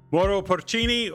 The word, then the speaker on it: Mauro Porcini: Mauro Porcini, welcome to the Everyday Millionaire Podcast.